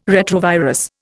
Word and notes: retrovirus: Indian

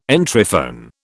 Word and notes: Entryphone: British English

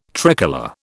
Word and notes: tricolour: British English

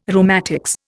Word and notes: rheumatics: Indian

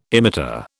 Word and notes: emitter: British English

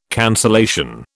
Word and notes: cancellation: British English

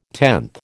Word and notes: tenth: American English

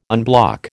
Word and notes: unblock: American English

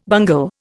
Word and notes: bungle: Indian

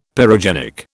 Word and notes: pyrogenic: British English

